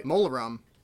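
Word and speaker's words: Mulheron: But Stephen Sinclair and Denny Mulleron, I hope I'm saying that right. – Mulleron.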